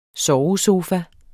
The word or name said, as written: SOVESOFAS